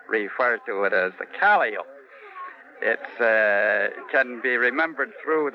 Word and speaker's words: Calliope: Well, according to Webster, it's pronounced Calliope after one of the Greek muses, but actually, Webster never played one.